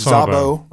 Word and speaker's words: Szabo: It's Szabo, right? – The Z is silent. – I always do say the Z, and I'm sorry about that. So just Szabo.